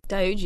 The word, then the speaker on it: Doge: I believe that's pronounced correctly. – Doge, yeah.